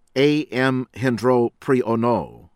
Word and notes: A.M. Hendropriyono: American English; Indonesia

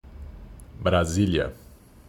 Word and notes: Brasilia: ptbr

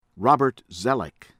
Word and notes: Robert Zoellick: American English

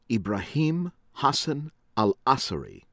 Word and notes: Ibrahim Hassan Al-Asiri: American English

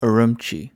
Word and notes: Ürümqi: English

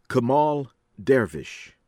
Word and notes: Kemal Dervis: American English; from Turkey

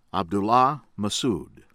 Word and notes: Abdullah Mehsud: American English

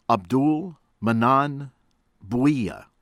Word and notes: Abdul Mannan Bhuiyan: American English

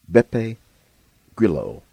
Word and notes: Beppe Grillo: American English

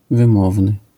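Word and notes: wymowny: Polish

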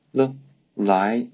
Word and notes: 来: Mandarin Chinese

